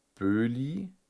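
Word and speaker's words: Boehly: Aber ähm, das hat der wie heißt der, ein US-Milliardär Böhli, ich glaube, so spricht man ihn aus, ähm, der ja, wollte gerne Ronaldo haben und man hat dem Tuchel keinen Wunsch erfüllt.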